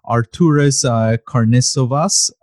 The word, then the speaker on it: Arturas Karnisovas: But Arturas Karnisovas,